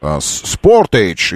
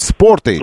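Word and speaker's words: Sportage: Он размером примерно как Спортаж. Спортэйдж, извините, надо правильно говорить. Спортэйдж.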